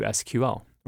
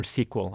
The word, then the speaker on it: SQL: Where do you fall on pronouncing SQL, SQL? – SQL. – SQL. All right. – Sure. – Okay. We'll go with SQL here. The official pronunciation of this podcast is SQL.